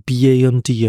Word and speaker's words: band: 像我们英文有一个 b a n d 啊，它叫做 band，那 b e n d 呢，这是 bent。那其实呢，a 的这个发音呢，它比较偏向是在你的梯形的靠近下巴的地方，也就是前端的下缘，所以它念法呢，会是变得。 a band，而 b e n d 的 a 它比较是中间，然后是在前端，所以呢，它应该是 bent。